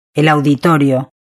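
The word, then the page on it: el auditorio: Spain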